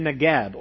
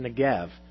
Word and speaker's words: Negev: And then there's this illustration here, like streams in the Negev, or sometimes it's pronounced Negev.